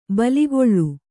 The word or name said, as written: ಬೇಲಿಕಳ್ಳಿ